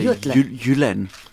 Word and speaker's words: Jylland: This band is from Denmark, including Jutland. Is that how you pronounce it, really? – With it is. J. Well, in Danish you say Jylland. Yeah, Jylland. Thank you.